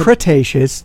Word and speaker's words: Cretaceous: It's Cretaceous. That's the pronunciation. Cretaceous.